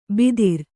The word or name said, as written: ಬಿದಿರ್